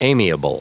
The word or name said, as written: amiable